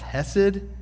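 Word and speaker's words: hesed (חסד): It's the word in Hebrew is pronounced hesed, and it's untranslatable.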